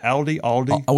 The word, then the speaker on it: Aldi: Am I saying that right? Aldi? – We say Aldi. – A-L-D-I. In our house. It's A-L-D-I.